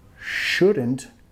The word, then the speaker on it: should: Should. La L muda. Sh. No es should. Should. No dando beso. Más e. Sh. Sh. Should. You.